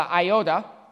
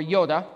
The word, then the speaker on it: iota: The manuscripts, the iota or yoda, depending on how you want to pronounce it, is just one line, just a, a quick tick.